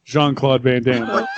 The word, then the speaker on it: Jean-Claude Van Damme: Rob, I was going to say Rob Job Van Damme, Jean-Claude Van Damme.